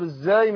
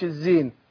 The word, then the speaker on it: الزاي: وطبعا أنا دايما بأكد يعني لإخواني إن ناخد بالنا من مسألة الإخفاء، يعني إخفاء النون هنا عند الزاي، حرف الزاي، وطبعا قلنا بننطق بنقول الزاي مش الزين، ماشي؟